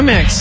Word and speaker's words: Amex: Is it Amex? Is that how you say it? – Yeah, Amex.